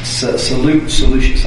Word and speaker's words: Seleucus: Seleucus, I believe, is the way it's pronounced.